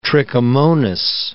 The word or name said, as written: trichomonas